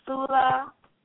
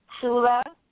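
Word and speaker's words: Phula: Dizzy is C-A-K-K-A-R-A, and it's pronounced Chicard. Chicard, Dizzy. Flower is the next word. It's pronounced Fula. It's P-H-U-L-A, Fula. The seventh word is mirror, and mirror is spelled A-I-N-A, and it's pronounced Anna.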